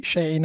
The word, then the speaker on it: Shein: Che si pronuncia così, Shein.